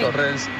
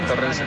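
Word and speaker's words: Torreense: O sea, Torrense, ¿no? Se dice así. – Torrense. Bueno, en Portugal, Tujiense, pero sí, Torrense.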